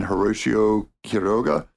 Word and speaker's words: Horacio Quiroga: So I think maybe coming up, we're going to have short stories by Edith Wharton and Horacio Quiroga. That's how you really pronounce it, folks, if you're not a stupid white redneck like me.